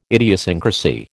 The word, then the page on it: idiosyncrasy: American English